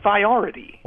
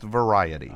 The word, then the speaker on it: Variety: It looks like it's pronounced Viority. – Well, no, it's pronounced Variety.